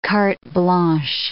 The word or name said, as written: cartes blanches